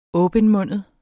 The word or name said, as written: åbenmundet